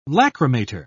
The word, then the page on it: lachrymator: British English